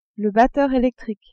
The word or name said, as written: batteur electrique